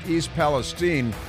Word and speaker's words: East Palestine: Maybe somebody should tell him that it's pronounced East Palestine so that he can pronounce the name of the town correctly that suffered a very traumatic hazmat incident with the train derailing there.